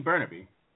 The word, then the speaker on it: Burnaby: Barnaby? What you mean Burnaby? – Yeah, whatever.